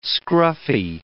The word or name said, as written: scruffy